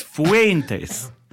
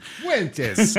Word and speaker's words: Fuentes: Syllable's got to rhyme with hey. It's Fuentes. – Fuentes.